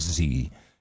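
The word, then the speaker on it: Xe: Hey, man, just pronounce it like Z. You know, like Q. – And it's X-E? – Capital X under lowercase e. But it says the parent company new name is pronounced like the letter Z.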